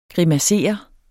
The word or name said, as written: grimassere